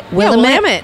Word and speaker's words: Willamette: Willamette.